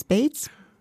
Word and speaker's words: Baez: Bates? – Genau, spricht man sie aus.